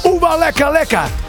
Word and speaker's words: Oeba Lekka Lekka: En we weten inmiddels ook hoe we het moeten uitspreken. De Oeba Lekka Lekka.